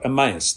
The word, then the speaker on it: Emmaus: How would you say it? Emmaus? Emmaus? Emmaus? There you go. Emmaus. Thank you.